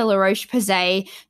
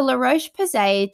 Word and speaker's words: La Roche-Posay: And then finally, I do want to talk about one really thick moisturizer that's really great for sensitive skins, and that's the La Roche-Posay Tolarian. Again, it's the French pronunciation. The La Roche-Posay Tolarian Ultra Sensitive Moisturizer.